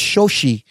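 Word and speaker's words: Xochitl: There's a TL at the end, but I think it's silent. I think it's Chochi Gomez, America Chavez, Rachel McAdams coming back as Christine Palmer, and other cameos we will get to as we discuss the movie.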